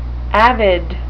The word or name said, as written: avid